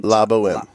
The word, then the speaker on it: La Boheme: La Boheme. It's I think the H is silent. – La Boheme.